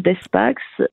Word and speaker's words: Despax: Alors, mon site, il suffit de taper mon nom dans Google, Marie-Caroline Despax, médiateur familial. D'accord. Et on tombe directement sur mon site. – Despax, c'est D-E-S-P-A-X. – Voilà, voilà. Donc, on prononce toutes les lettres.